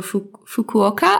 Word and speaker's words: Fukuoka: Fukuoka.